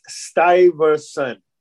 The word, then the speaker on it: Stuyvesant: And the pronunciation of the name of the town, I want to mention to everybody, is Stuyvesant. We even have a high school in New York called Stuyvesant High School.